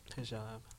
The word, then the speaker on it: hijab: hijab.